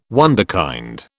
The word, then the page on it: wunderkind: British English